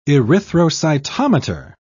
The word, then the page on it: erythrocytometer: British English